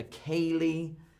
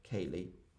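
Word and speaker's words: ceilidh: It's called a Cayley. It looks like it would be pronounced differently, but we pronounce it Cayley.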